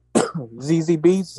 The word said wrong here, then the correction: Zazie Beetz: ZZ Beetz. I don't know how to say her name. Zazie. Zazie Beetz. Yeah, Zazie Beetz.